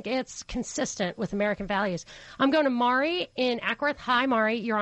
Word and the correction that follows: Marie: Mari, am I not saying your name right? – It's Marie. – It's Marie. The E fell off. I'm super sorry. Hey, Marie.